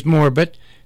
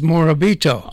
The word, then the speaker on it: Morabito: Charlie was Morbit. Carmen was Morbit. But Mike was Morabito. – Oh. – The Italian pronunciation.